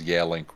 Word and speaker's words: Yealink: The vast majority of you for being like one of the three people I know that actually pronounces Yealink right. I don't even say Yealink. That is the proper pronunciation. I say Yealink. – Oh, really? – Like everybody else.